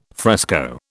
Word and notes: fresco: British English

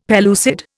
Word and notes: pellucid: Indian

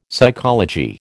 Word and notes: psychology: American English

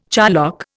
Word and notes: charlock: Indian

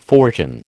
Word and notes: fortune: American English

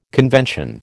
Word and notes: convention: American English

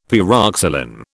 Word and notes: pyroxylin: British English

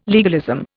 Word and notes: legalism: Indian

